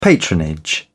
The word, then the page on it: patronage: British English